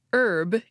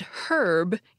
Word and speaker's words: herb: For example, the word for a certain kind of plant is pronounced herb in American English and herb in British English.